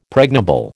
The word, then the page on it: pregnable: American English